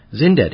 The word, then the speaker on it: Xyndet: Zindet, it's spelled X-Y-N-D-E-T.